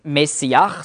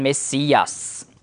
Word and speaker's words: Messias: Es gibt übrigens keinen Sch-Laut im Griechischen. Darum hat das automatisch einen S-Laut gegeben. Also nicht Masch, schon Mess. Und sie haben dann nicht gesagt Messiach, sondern Messias. Das ist also die griechische Aussprache.